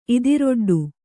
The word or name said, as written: ಇದಿರೊಡ್ಡು